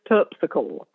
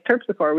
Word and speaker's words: Terpsichore: So it's this guy, this channel on YouTube that does pronunciations, and he has a very British accent, and he's like, this is how we pronounce. And so it was Terpsichore. And so it's like, okay, it's Terpsichore. We get it now.